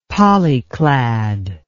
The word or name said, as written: polyclad